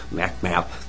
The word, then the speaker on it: MacMap: I work for a statewide group, MacMap, that's how you say it in it's short,